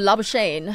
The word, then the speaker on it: Labuschagne: Oh, Labuschagne.